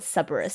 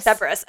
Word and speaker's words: Cerberus: I think most people call it Cerberus. – Cerberus. Okay.